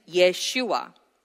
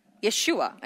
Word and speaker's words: Yeshua: So Jesus here, because we're reading English, an English translation, but in Aramaic and in name, in the original pronunciation, his name is Yeshua. Yeshua. And it's connected to Yehoshua, which is anglicized to Joshua, which means the Lord saves.